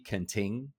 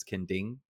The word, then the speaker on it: Kenting: When I first came here, there's a place people like to go, it's called Kending, okay, but it's spelled K-E-N-T-I-N-G, Kenting, which I still know foreigners that say Kenting, but it's Kending. Yeah. So the T is supposed to be a D sound.